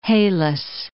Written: Halys River

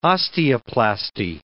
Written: osteoplasty

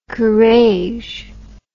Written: Courrèges

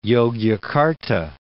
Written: Yogyakarta

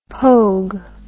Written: pogue

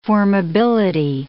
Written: formability

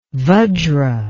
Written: vajra

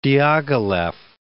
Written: Diaghilev

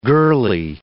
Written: girlie

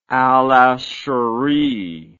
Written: al-Ashari

